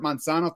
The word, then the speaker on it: Manzano: He is Gilbert Manzano. Thanks very much for joining us today. – No problem. And now I'm glad I came on the show because you pronounced my last name perfectly and you said compas perfectly.